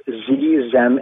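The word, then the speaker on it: xem: Well, it's spelled X-E-X-E-M and X-Y-R, and it's pronounced Z-Zem and Zir.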